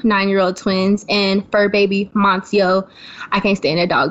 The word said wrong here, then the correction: Moncho: His name is Moncho. – Moncho, is Moncio. – That's Moncho, how you say it.